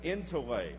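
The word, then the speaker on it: entole (ἐντολή): It goes like that, and it's an eta. And it's pronounced as an A. That's why we pronounce it entole.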